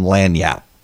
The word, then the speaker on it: lagniappe: We believe in lagniappe in Louisiana, which is not spelled the way it's pronounced.